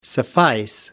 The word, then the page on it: suffice: American English